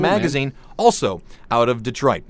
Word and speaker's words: Detroit: It's pronounced Detroit. Okay. At least that's the way I always pronounced it, and I lived in Cleveland, the next major city over. – Okay. – So that's we always called it Detroit.